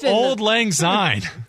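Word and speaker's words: Auld Lang Syne: Odd Lang Sang? How do I get it? Oh my God. That's the most unsportsmanlike word here. – It's What's wrong with my pronunciation? – It's Old Lang Syne.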